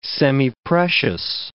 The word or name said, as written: semiprecious